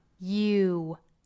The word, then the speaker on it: you: 因为 you 这个单词其实是有一个半元音 ye 和后面的长音 u 组合在一起的。那么这个耶的发音过渡到长音乌就会有这样的一个口型的过渡 you 一个耶的口型到乌的过渡 you